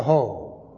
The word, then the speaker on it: Hoc: The thing that I would like to emphasize today more than anything else is what happened at Pointe de Hoc. That's spelled H-O-C, but it is pronounced in the French, Ho.